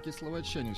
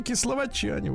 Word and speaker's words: кисловодчане: кисловодчане все-таки. – Дорогие мои кисловодчане, вот так надо правильно говорить.